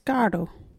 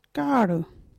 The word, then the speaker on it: gaardu: Our local Wajiri word is gardu. What is a gardu? Gardu is playing cards. You can use gardu in this sentence. All the woman this one, gardu. Gardu is spelt G-A-A-R-D-U.